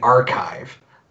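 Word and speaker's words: arXiv: Yeah, so one of the best resources is what's called the archive, but it's spelled A-R-X-I-V. A-R-X-I-V.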